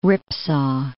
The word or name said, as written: ripsaw